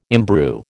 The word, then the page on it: imbrue: American English